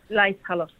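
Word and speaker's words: Laeiszhalle: At the Leishhalle, right? Am I saying that right? – Leishhalle? Leishhalle, yeah.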